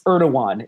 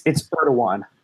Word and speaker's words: Erdogan: It's Erdogan, is how it's pronounced. It doesn't look like it should be pronounced. – It looks like Erdogan. – Yeah, it's Erdogan.